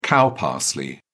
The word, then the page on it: cow parsley: British English